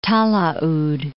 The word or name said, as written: Talaud Islands